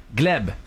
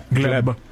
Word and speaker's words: Gleb: Gleb, c'est comme ça qu'on prononce son nom. – Gleb.